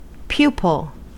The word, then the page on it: pupil: American English